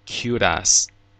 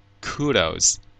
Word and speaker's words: kudos: kudos 有两种发音，英式发音中常读作 kudos，而美式发音中则读成 kudos。